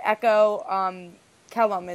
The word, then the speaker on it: Echo Kellum: We have Echo Kellum is how you pronounce his last name, right? – Yeah, I don't want to butcher it.